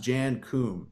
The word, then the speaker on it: Jan Koum: Now he is Ukrainian, so I believe it's pronounced Jan Koum.